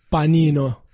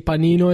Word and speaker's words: panino: So if you like panini, it's actually panino.